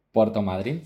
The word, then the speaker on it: Puerto Madryn: And you're based in the northeast of Patagonia in a city called, and I'm going to attempt to pronounce this. If I pronounce it wrong, please correct me. But in a city called Puerto Madryn? – Puerto Madryn, sí. Excellent.